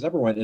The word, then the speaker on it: Eberwein: Yeah, so I always thought people would be, you know, my last name is Eberwein. And first of all, you pronounced it correctly, which is unheard of. Almost everybody gets it incorrect. They say like Eberwein or, you know.